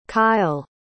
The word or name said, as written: kail